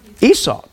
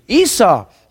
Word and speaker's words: Esau: Did I say it right? Esau? How do you say it in English? Esau. Thank you. Esau.